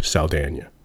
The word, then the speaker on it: Saldana: And so Zoe's name tricks me up because she's got an accent on the N, but she doesn't have an accent on the E. So it should really be Zoe Saldana.